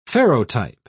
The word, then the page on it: ferrotype: British English